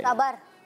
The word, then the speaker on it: सब्र: खरीदने वाला, आम, बोलने, सब्र, कारोबार वाला। दिखाएंगे स्क्रीन पर सवाल क्या है। किसका फल मीठा होता है? – सबर। – सही प्रोनाउंसिएशन है सब्र, मगर आपका जवाब दुरुस्त मानते हैं।